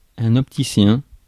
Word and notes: opticien: France; [ɔp.ti.sjɛ̃]

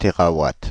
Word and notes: térawatt: [te.ʁa.wat]; /te.ʁa.wat/

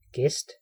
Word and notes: convive: IPA: /kɔ̃.viv/; French; France

